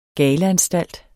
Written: galeanstalt

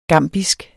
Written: gambisk

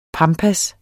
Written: PAMPAERNE

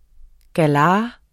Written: galar

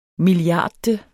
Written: milliardte

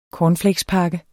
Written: cornflakespakke

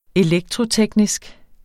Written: elektroteknisk

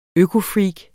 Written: økofreak

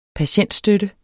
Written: patientstøtte

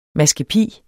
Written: MASKEPIS

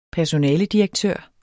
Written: personaledirektør

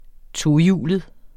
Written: tohjulet

